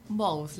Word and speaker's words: Bohus: Bohus, la H Bohus. Es muda en castellano. Dicen que el origen es con J, pero bueno.